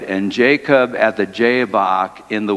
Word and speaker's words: Jabbok: So the dramatic encounter happens at the river Jabbok, which rhymes with Jacob.